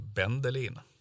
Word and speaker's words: Bendelin: För alla som kommer någon annanstans än från Gotland, och det är väl i slutändan de allra flesta antar jag. De uttalar det ungefär så som du gjorde. Men mer lokalt så skulle det nog bli med Bendelin.